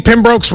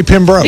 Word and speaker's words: Pembroke: Why can't I say Pembroke? – Because it's Pembroke.